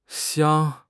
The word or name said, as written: xiang1